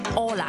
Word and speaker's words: Hola: 但在西班牙文 H 是永远都是不发音的，我们就会读 Hola。